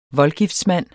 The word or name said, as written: VOLDGIFTSMANDEN